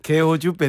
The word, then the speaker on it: 개호주: 개우주 밴드죠. 이렇게 좀. – 아, 부럽습니다. 죄송하지만 발음을 좀 제대로 해주시면 안 돼요. – 개호주 밴드죠.